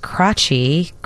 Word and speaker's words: Krajci: Honoring my brothers, Jeff, K-R-A-J-C-I. They say it's pronounced Crachy, I hope, and Gary Crachy. – It could have been Crotchety.